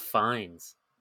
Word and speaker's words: Fiennes: So I don't know if his last name is pronounced Fiennes, which is what I thought for the entirety of my life, or if it's literally pronounced Fiennes, like he is he finds me, but no D. And You're right. It's Ralph Fiennes.